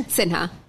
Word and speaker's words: Sinha: Sinha.